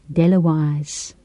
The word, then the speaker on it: Dale of Walls: This title poem of the collection is set in the uninhabited western edge of Shetland, near Dale of Walls, pronounced Delawise.